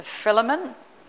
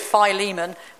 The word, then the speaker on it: Philemon: Said it right, Bethany? So many people have told me down the years it's Philemon. It's not, because it's from the Greek word phileo, so it's got to be a phil.